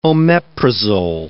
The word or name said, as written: omeprazole